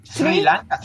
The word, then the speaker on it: Sri Lanka: Es Sri Lanka. ¿Sí se pronuncia así? – Sri, Lanka. Sri Lanka.